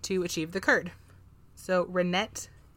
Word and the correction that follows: rennet: is or Renee Renee How do you spell it? – R E N N E T. I think it's rennet. Rennet.